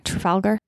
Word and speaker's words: Trafalgar: Am I saying that right? Trafalgar? – Mm-hmm.